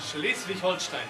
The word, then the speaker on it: Schleswig-Holstein: A ver, say it in German. Schleswig-Holstein. – Schleswig-Holstein. – Schleswig, ahí estuvo el detalle.